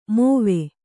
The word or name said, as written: ಮಾವಿ